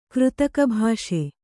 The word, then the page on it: ಕೃತಕಭಾಷೆ: křtaka bhāṣe